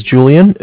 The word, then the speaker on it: Julian: All right, my name is Julian. It's spelled J-U-L-I-A-N.